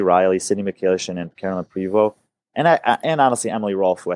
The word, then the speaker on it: Prevost: Because I bet you Carolyn Prevost, am I saying her name right? I think she's going to do. Prevost. Yeah. She's going to do incredible in that last event.